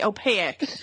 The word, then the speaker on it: opaque: It's one syllable or two syllables, not three. Opaque.